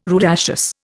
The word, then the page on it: rudaceous: Indian